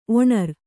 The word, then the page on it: ಒನರ್: onar